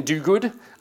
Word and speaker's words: Duguid: His name's Ian Duguid. I only just figured out how to pronounce that recently. Duguid is how it's spelt.